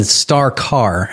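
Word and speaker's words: Star Carr: So you found the pendant in Star Car.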